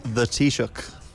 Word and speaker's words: Taoiseach: So the prime minister is the Taoiseach.